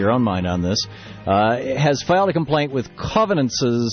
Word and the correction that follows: Covance: Am I pronouncing the company name right, Alex? – I believe it's Covance. – Covance, thank you.